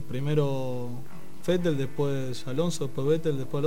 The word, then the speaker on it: Vettel: No hincha, pero lo prefiero antes que Vettel, ¿cómo se pronuncia? – Vettel. – Vettel, lo prefiero.